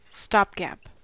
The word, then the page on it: stopgap: American English